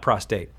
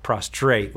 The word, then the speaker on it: prostrate: You see bowing and kneeling and get on your face and prostrate and not prostate, prostrate.